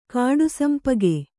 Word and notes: ಕಾಡುಸಂಪಗೆ: kāḍu sampage

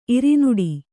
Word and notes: ಇರಿನುಡಿ: irinuḍi

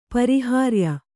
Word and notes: ಪರಿಹಾರ್ಯ: pariyārya

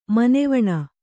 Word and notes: ಮನೆವೆಣ್ಣು: maneveṇṇu

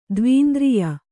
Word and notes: ದ್ವೀಂದ್ರಿಯ: dvīndriya

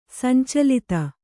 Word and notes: ಸಂಚಲಿತೆ: sancalite

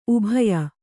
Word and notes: ಉಬಯ: Kannada

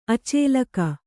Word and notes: ಅಚೇಲಕ: acēlaka

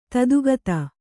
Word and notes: ಧಾತುಗತ: Kannada

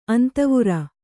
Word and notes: ಅಂತವುರ: antavura

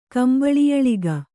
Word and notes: ಕಂಬಳಿಯಳಿಗ: kambaḷiyaḷiga

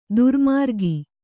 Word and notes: ದುರ್ಮಾರ್ಗ: durmārga

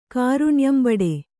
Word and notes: ಕಾರುಣ್ಯಂಬಡೆ: kannada

